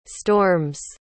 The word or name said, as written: storms